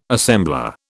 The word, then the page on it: assembler: British English